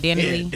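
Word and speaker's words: Dani Leigh: Danny Lee? – Danny Lee, yeah, she fine.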